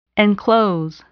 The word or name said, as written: enclose